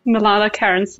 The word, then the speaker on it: Milana Kearns: Did I say it right? – That's fine. That's perfect. Milana Kearns.